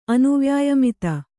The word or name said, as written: ಅನುವ್ಯಾಯಮಿತ